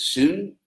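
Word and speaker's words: soon (σύν): The Greeks spelled it S-Y-N and pronounced it soon, sum.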